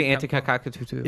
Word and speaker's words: Antetokounmpo: Never know how to say his last name, but his first name is Giannis. Giannis Antetokounmpo. – It's actually Antetokounmpo.